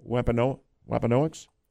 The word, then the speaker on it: Wampanoag: If I have it right, Wampanoags? – Wampanoag.